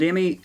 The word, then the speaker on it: Demme: It's directed by Jonathan Demme, and we are saying his name correctly. I very rarely hear it actually said, but we did check up on this.